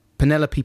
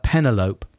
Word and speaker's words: Penelope: Two, Penelope Pussycat from Looney Tunes, always pronounced as Penelope. – Penelope, that's good. – Yeah, this is hard to spell phonetically, so you just pronounce it the same as envelope.